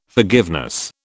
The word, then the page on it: forgiveness: British English